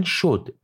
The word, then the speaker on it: شد: مثلا شد بسته به اینکه موقع تلفظ استرسش روی شین باشه یا روی دال باشه معنیش عوض میشه و در حالت اول معنی رفت و تموم شد معنی میده و در حالت دوم معنی انجام گرفت